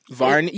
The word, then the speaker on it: Varney: Varney? Yeah, it rhymes with Barney.